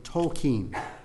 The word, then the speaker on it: Tolkien: For the record, Tolkien pronounced his surname Tolkien.